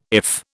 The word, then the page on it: IF: British English